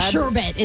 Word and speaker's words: sherbet: Sherbet is how you're supposed to say it, but sherbet.